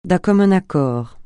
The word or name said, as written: d'un commun accord